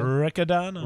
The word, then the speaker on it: Riccadonna: Riccadonna.